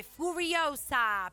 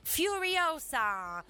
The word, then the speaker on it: Furiosa: Lei interpreta una giovane Furiosa, perché si pronuncia così, raga, non ridete. Furiosa, la badass driver portata a successo da Charlize Theron nel precedente capolavoro Fury Road.